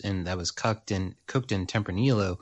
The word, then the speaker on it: Tempranillo: Say it one more time. One more time. – One more time. – I keep cutting you off. – Tempranillo. – There we go. Perfect. – Okay. Tempranillo.